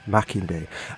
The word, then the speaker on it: Makinde: Adeyinka Makinde, for a discussion on his soon-to-be-published article with the intriguing title Intelligence Accountability, Can the British State Convict Itself?